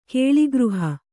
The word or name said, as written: ಕೇಲಿಗೃಹ